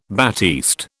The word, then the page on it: batiste: British English